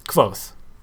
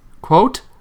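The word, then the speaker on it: Kvothe: and most important person that you need to know is Quoth. – Quoth? Quote? – Quoth. Quoth. With a th. Quoth. – Like the raven.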